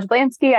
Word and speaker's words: Jablanski: My name is Danielle Jablanski.